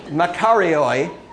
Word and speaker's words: makarioi: M-A-K-A-R-I-O-I in English letters. – It's Makarioi. Did I say that right? Okay, good. Because I know last time I tried to say it, it came out Macarena.